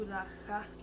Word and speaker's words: pulagaa: Is that with a G? Pulacha. – Pulacha. Yeah, Pulacha. – Pulacha. – How did you spell that? P-U. – P-U-L-A-G-A-A. – Mm-hmm. – Pulacha.